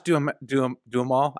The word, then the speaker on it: Duhamel: Josh Duhamel.